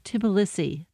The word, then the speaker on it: Tbilisi: And we should say that's spelled T-B-I-L-I-S-I? – Yes, Tbilisi.